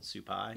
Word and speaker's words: Supai: So that helicopter will go straight into, and if I said it right, is it Supai? – Yes.